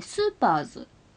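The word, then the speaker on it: セーラームーンSuperS: で、えー、シーズン 4 が、えー、美少女戦士セーラームーン、えー、スーパーズと読みます。これが、えっ、ー、と、 SS だったんですけど途中でちょっと表記が変わったみたいでスーパーズというふうになってますね。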